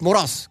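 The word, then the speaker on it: Мораз: Патрик, Патрик Мурас, как правильно говорить, мне сказали.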